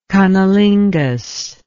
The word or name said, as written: cunnilinguist